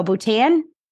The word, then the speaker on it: Bhutan: Bhutan.